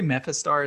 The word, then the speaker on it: Mephistar: Yeah, I say Mephistar. It's like the capital Mephistar.